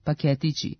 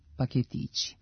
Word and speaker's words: paketići: U skladu sa tim bismo mogli da pretpostavimo da nominativ množine ove imenice može biti samo paketići, sa kratkouzlaznim akcentom na drugom slogu. Međutim, kao i većina deminutivnih oblika sličnih imenica tipa kaputići, kaputići, šeširići, šeširići, i imenica paketići ima regularan dubletni oblik sa dugouzlaznim akcentom na trećem slogu paketići.